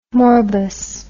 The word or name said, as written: morbus